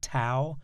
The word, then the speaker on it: Tao: If we look at the Tao, by the way, Tao is spelled T-A-O, often pronounced Tao, but the correct pronunciation is D as in dog, Tao.